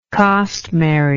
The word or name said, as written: costmary